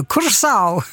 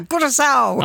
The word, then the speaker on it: Curaçao: Då vet ju du hur man uttalar landets namn. – Ja, man säger Curaçao. – Curaçao. – Ja, Curaçao.